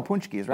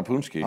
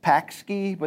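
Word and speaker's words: pączki: Is that like It looks like pakski, but the true Polish heads know it's punchki, all right? – All right, punchki.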